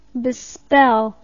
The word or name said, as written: bespell